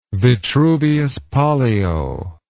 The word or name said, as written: vitruvius pollio